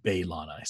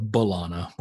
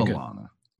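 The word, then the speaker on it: B'Elanna: So we have Hogan talking to Torres, and he says Baylana. So he pronounces it the way that Roxanne pronounces it sometimes, right? – Baylana instead of Bulana, which is the way I've been doing it forever. – Listen, I'm married to her. I know how to say it.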